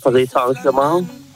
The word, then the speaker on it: Paris Saint-Germain: Ja, Paris Saint-Germain.